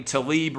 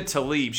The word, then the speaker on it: Tlaib: Finally, I believe you pronounce her name Tlaib, Rashida Tlaib.